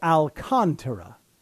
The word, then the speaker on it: Alcantara: The accent is on the second syllable. So Alcantara. Alcantara.